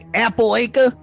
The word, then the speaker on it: Appalachia: It's actually Appalachia. – Yeah. – Yeah, I live over in Appalachia. – I mean, Michael has a CH and we pronounce it like a K. Yep. So maybe it is supposed to be Appalachia.